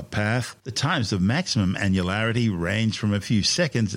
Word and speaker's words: annularity: The path of annularity, and yes, that's how it's said,